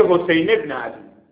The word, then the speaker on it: ابن: واژه ابن، واژه ابن میگه حسین ابن علی. خب چرا همزه انداختی؟